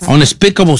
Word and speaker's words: Unspeakable: Unspeakable. – UN y luego Speakable Sins. – Unspeakable Sins. Ok, yeah. 2025, o sea que es nueva. ¿Dónde la podemos ver? – En Netflix, viejo. – Netflix, Unspeakable Sins, pecados inconfesables.